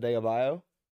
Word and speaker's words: Adebayo: Is that how I said it? Adebayo? – Yeah. Got it right.